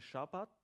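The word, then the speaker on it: Shabbat (שבת): Das hebräische Wort Sabbat, oder wie es eben ausgesprochen wird im hebräischen Shabbat, heisst nichts anderes als Ruhepause, Ruhetag.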